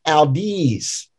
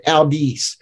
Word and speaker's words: audis: But the trouble is the first syllable is long too. The AU is long. It's so you've got to kind of compensate on that. So you got to say audis. But you kind of accent them kind of the same. They're what it is with Latin, both it's like music. Both those syllables are half notes, audis, so you kind of hold them out.